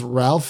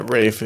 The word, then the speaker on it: Ralph: and I've always thought it was Ralph Fiennes, but I thought Rafe Fiennes? – Yeah, Rafe is what I've heard. It's interesting pronunciation for this Brit.